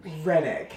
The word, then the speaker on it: Roenigk: Oh, but Roenigk.